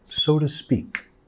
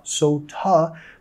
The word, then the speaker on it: so to speak: Nota la pronunciación acá. So to. Vamos a reducir el to a un ta. Ta, sonido de schwa, so ta, pero también vamos a vincular las dos palabras con una R suave.